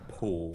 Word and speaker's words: Paul: I wish Americans pronounced my name Pool.